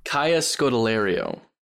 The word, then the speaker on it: Kaya Scodelario: Kaya Scodelario.